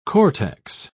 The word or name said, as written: cortex